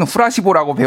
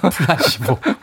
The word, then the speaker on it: 플라시보: 그래서 저는 후라시보라고 배웠는데 발음이 구리 어, 좋지 않다는 뭐 지적을 제가 받았습니다. 후라시보라니요. 플라시보.